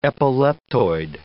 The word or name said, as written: epileptoid